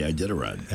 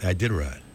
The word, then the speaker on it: Iditarod: The Iditarod. – Iditarod. – Thomas Werner. Won it. He crossed the finish line in Nome, Alaska, in first place on Wednesday to win the Iditarod Trail Sled Dog Race, the sport's most famous competition, in just his second attempt.